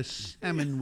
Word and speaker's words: salmon: En español se dice salmón, pero en inglés se dice salmon. No se dice salmon. No. – Okay. – Se dice salmon. – Entiendo. – Eso, no me pregunte por qué. La L es silente en esa vaina.